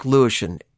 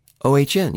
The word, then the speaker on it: Lewisohn: Check it out, Mark Lewisham, Lewisohn.